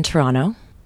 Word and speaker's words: Toronto: So we're here in Toronto. That's how you say it as a Canadian.